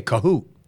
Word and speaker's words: Kohut: Same spelling as yours, but they'd say Kohut. Yours is. No, they'd say Kahoot. And you're a Kohut guy. – I think Kahoot is probably the original pronunciation.